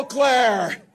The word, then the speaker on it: Eau Claire: Hello, Eau Claire.